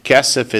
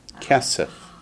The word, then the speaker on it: kesef: Kesef is silver or money. What was gold? – Zahav. – Silver is kesef. Kesef. – Like K-E-S-E-F.